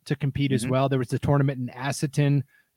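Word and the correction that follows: Asotin: Am I saying that right? – Asseton? No, you are not. That is Asoton.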